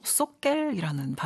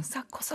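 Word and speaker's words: Socker: 근데 이게 그 스웨덴어로 쏘겔이라는 발음이더라고요. – 쏘겔. – 쏘겔이라는 발음이더라고요. – 네. – 그래서 진짜 이번에 처음 찾아봤거든요. 저도 그냥 싸커 싸커 싸커 싸커 이렇게 들었는데 쏘겔이었고요.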